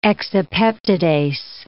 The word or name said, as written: exopeptidase